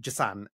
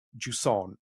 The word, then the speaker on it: Jusant: And yes, it's not Jusson, as I said in my really like tame English accent or anything else. Yes, this is Jusson that we have coming out.